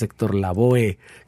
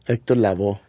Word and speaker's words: Lavoe: Que cabe destacar, me acabas de sacar de la ignorancia hace poco, que no es Héctor Laboe. que todo el mundo aquí le dice así porque se escribe Héctor Lavoe. Héctor Lavoe.